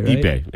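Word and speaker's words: Ippei: And is that how you say his first name? – Ipe Mazuhara. – Yeah. I'm saying that right? – Ipe. Ipe.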